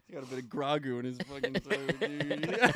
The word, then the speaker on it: Grogu: It's Grogu.